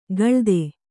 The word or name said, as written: ಕಳಾದ